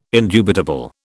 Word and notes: indubitable: British English

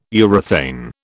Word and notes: urethane: British English

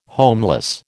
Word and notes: homeless: American English

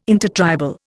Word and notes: intertribal: Indian

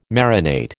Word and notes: marinate: American English

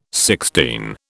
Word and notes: sixteen: British English

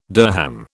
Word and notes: dirham: British English

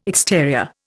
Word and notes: exterior: Indian

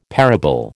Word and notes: parable: American English

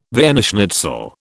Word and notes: Vienna schnitzel: British English

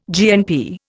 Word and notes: GNP: Indian

